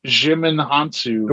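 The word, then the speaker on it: Djimon Hounsou: Jimin Hansu. – The wizard. – D is silent.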